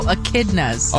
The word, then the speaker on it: echidnas: Oh, echidnas.